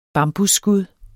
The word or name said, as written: bambusskud